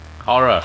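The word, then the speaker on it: HO99O9: Horror. – Horror. – Allegedly pronounced horror, but it's spelled H-O-9909.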